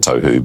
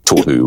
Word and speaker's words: Tohu: Tohu, always a hard one to pronounce. A lot of New Zealanders as well would say it tohu, but the tohu would be the sort of correct pronunciation, if you like.